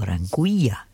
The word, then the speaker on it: Anguilla: This one's called, and it's his latest, Anguilla, or Anguilla, if you pronounce it in Spanish, A-N-G-U-I. Double L-A is Sunburn. Sunburn, of course, is spelled all in lowercase, S-U-N-B-V-R-N.